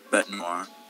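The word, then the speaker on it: Bête Noire: Bete Noire.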